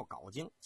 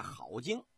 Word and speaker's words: 镐京: 咱们在这儿给您说一下，老百姓啊管这个地方叫镐京，其实应该啊念好京，它是个多音字。